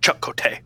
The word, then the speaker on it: Chakotay: Also, pay attention to the way that people are pronouncing his name. Like, everybody's kind of like Chakotay. Anytime Torres B'Elanna says it, she's like, Chakotay. – She does it all with this, like, exotic verve. – She knows him. She knows the right way to say his name.